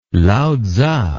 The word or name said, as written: lao tzu